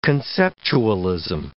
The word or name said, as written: conceptualism